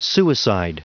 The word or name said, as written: suicide